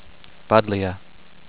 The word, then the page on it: Buddleia: bud-LEE-ah